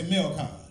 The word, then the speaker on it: Melkon: Everybody say Melkon. – Melkon is spelled M-E-L-K-O-N.